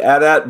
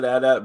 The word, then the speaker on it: AT-AT: It's officially AT-AT, but both are okay. So I know the toy, they always said AT-AT, but Lucasfilm actually came out and said it's AT-AT, but we've had in canon both pronunciations.